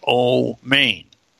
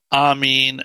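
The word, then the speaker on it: Omein (אמן): the Nikud indicates that it would be pronounced Omein. And they're little, you know, within different, you know, you'll hear, like, for example, some Jews say it Amin, and some Jews will say Omein, and some, and of course, even in the Eastern Christian churches, they say Amin.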